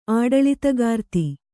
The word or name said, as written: ಆಡಳಿತಗಾರ್ತಿ